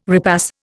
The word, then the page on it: repass: Indian